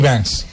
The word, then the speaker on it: Vance: Vance.